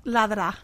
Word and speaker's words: Ladra: Helping us to do so in the studio is Pulitzer Prize-winning journalist Elaine Devaye, a.k.a. Ladre. Did I say that right? – Ladra. – Ladra. Okay. Ladra.